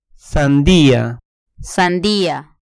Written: sandia

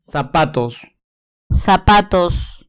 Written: zapatos

